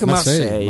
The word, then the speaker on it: Marseille: Sì, Olympique Olympic Marseille.